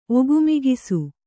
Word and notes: ಒಗುಮಿಗಿಸು: Kannada

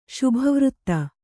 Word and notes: ಶುಭವೃತ್ತ: Kannada

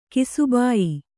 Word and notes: ಕಿಸಿಬಾಯಿ: kisibāyi

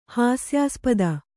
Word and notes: ಹಾಸ್ಯಾಸ್ಪದ: Kannada

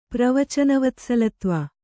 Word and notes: ಪ್ರವಚನವತ್ಸಲತ್ವ: Kannada

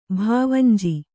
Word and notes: ಭಾವಂಜಿ: bhāvanji

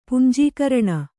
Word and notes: ಪುಂಜೀಕರಣ: kannada

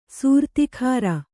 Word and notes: ಸೂರ್ತಿಖಾರ: sūrtikhāra

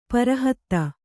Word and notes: ಪರಹತ್ತ: para hatta

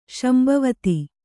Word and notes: ಸಂಭಾವಿತ: sambhāvita; Kannada

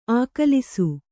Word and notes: ಆಗಲೀಸು: āgalīsu

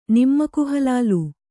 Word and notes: ನಮ್ಮಕ್‍ಹಲಾಲ್: nammak halāl

